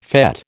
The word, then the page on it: fête: American English